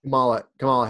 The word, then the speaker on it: Kamala: Kamala, how do you pronounce her name? – Kamala Harris, yeah.